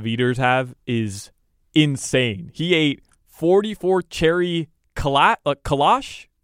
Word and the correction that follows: kolache: Kalash, is that how you pronounce it? Kolaches. – Oh, those kolache. That's kolaches.